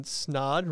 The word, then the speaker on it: Snaadt: Today I have Ryan Snaadt, rhymes with odd, here on the podcast.